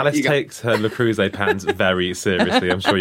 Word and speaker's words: Le Creuset: How did you pronounce that? – Le Creuset. – How do you pronounce it? – I think it's La Creuset. – Babe, it ain't. – It may not be, but it's definitely not Le Creuset.